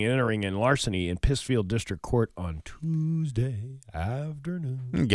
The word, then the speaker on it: Pittsfield: I believe it's pronounced Pittsfield. – Have you ever been there? – I have. – I don't think you know how to pronounce it properly.